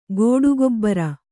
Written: ಗೋಡುಗೊಬ್ಬರ

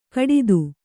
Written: ಕುಡಿತೆ

